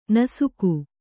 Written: ನಾಶಕ

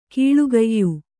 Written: ಕೀಳುಗಯ್ಯು

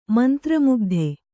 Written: ಮಂತ್ರಮುಗ್ಧೆ

